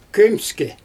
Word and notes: kömskE: Xyzyl

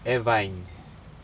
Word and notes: evaiN: Aneityum

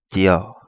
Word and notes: Tiahu: Kapingamarangi

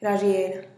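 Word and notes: razhiel: Teotitlán del Valle Zapotec

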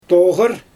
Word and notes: toɣɯr: Xyzyl

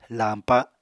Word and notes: lañpa: Apatani

